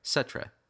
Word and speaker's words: Cetra: C-E-T-R-A. Setra. Setra city.